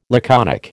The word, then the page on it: laconic: American English